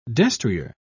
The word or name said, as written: destrier